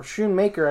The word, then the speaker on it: Schoonmaker: Second round, Luke Schoonmaker, or Schoonmaker, I believe is actually how he pronounces his last name, tight end, Michigan.